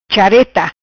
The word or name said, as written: Chareta